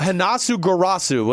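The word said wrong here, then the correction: Hroniss Grasu: Hronis Grasu.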